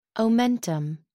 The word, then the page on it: omentum: American English